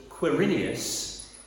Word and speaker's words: Quirinius: was Quirinius.